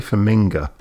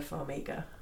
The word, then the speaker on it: Farmiga: I'd say Farminga, but I don't think that's right either. I think it's Farmiga. – I don't think we should be asking the dyslexic person how to pronounce Vera Farminga. No, it's Farminga because it's I-N-G-A. Farminga. – Farmiga. – I disagree. – Okay. It's Vera Farmiga.